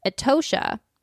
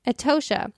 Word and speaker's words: Etosha: Its flagship park, Etosha, is that how you say it? Etosha. – I think so. Mm-hmm. – Etosha offers superb wildlife watching, but it's the Namib Desert which sets the country apart.